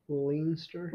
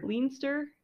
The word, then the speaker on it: Leinster: Leinster. – Leinster. L-E-I-N-S-T-E-R.